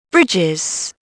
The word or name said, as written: bridges